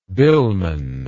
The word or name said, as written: billman